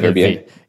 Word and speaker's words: Verbier: Verbier? It's French, so I don't know how to pronounce it, but it starts with Verbier. – Verbier. Yeah.